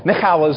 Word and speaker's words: Michal: Michal is, I just pronounce it that way because that's correct, and also because she's not very nice.